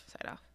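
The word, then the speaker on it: Siedhoff: It's S-I-E-D-H-O-F-F. – Seedhoff? – Seedhoff. That's what I thought, too. And then I was like, I don't know. Yeah. Seedhoff after, so George Seedhoff, geez.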